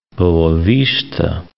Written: Boa Vista

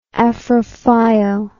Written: afrophile

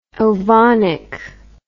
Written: ovonic

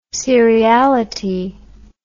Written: seriality

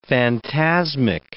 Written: phantasmic

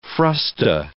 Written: frusta

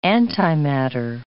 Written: antimatter

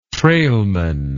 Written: trailman

